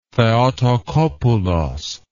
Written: theotocopoulos

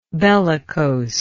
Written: bellicoseness

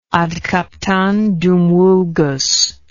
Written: ad captandum vulgus

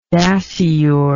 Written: dasyure

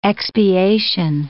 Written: expiation

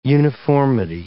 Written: uniformity

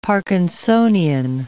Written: parkinsonian

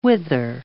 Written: wither

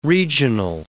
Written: regional

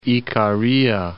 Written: Ikaria

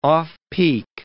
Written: off-peak

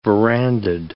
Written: verandaed

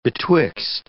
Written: betwixt

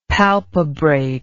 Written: palpebrate

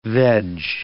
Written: veg

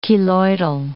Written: keloidal

